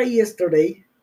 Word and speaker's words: yesterday: La palabra yesterday está escrita con Y, Y-E-S-T-E-R-D-A-Y. Y-E-S-T-E-R-D-A-Y. Entonces podríamos escuchar como ye, ye. Y no, vamos a pronunciar como si fuera hielo, hierba.